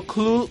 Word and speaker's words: Cthulhu: Lovecraft transcribe la pronunciación de Cthulhu como Clu, Clu o algo así.